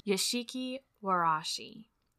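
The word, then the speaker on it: Zashiki Warashi: This week, I'll be telling you about the phenomena that is the Yashiki Warashi. And that's spelled Z-A-S-H-I-K-I space W-A-R-A-S-H-I.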